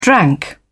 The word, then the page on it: drank: British English